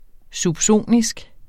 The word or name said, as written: subsonisk